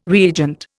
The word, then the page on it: reagent: Indian